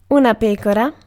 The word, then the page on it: sheep: [ʃip]; /ˈʃiːp/